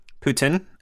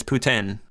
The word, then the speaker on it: poutine: We actually pronounce it, I mean, like, a lot of Canadians will pronounce it poutine as well, or poutine, I should say, is the way that a lot of people pronounce it. but the proper pronunciation is Putin.